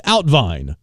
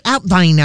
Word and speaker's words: uitwaaien: Spelled U-I-T-W-A-A-I-E-N. Or just Outvine. – Outvine? – Outvine. That's the pronunciation. A Dutch word meaning walking or exercising on a especially windy day. – How'd you get Outvine out of that? – You know, it's foreign.